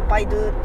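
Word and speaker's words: 百度: の 100 度って書いてパイドゥって読むんですけどそれが、うん、どういう意味なのか分かんないんだけどまあパイドゥ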